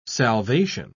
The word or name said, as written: salvation